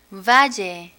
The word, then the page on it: valle: Spanish